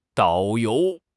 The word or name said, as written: dao3 you2